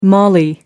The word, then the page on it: Mali: American English